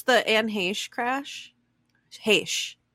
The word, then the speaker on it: Heche: I've heard a lot of pronunciations, but my mom is like hella into Hollywood, so it's Anne Heche.